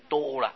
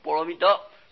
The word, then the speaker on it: 波罗蜜多: 一般人就念成多了，念成多，实际上只是一个轻轻的弹舌，舌头轻轻弹一下的声音调，波罗蜜多，所以旧译没有这个多，旧译就没有。